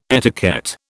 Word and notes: etiquette: British English

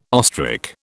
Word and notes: ostrich: British English

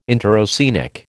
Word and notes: interoceanic: American English

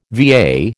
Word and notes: VA: American English

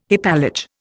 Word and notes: hypallage: Indian

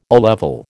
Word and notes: O level: American English

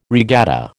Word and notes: regatta: American English

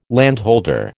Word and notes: landholder: American English

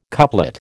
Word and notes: couplet: American English

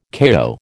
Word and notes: kayo: American English

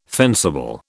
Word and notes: fencible: British English

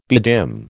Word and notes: bedim: American English

